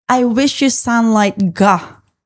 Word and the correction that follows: I Wish You Sunlight: It's just I Wish You Sunlight. The reason you see Ga written on the track is it's the final one.